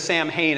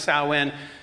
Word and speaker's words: Samhain: It looks like it says Samhain. It's pronounced Samhain.